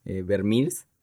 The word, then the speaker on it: Vermeer: Esa historia se conoce como los falsos Vermeers,